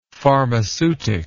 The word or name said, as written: pharmaceutics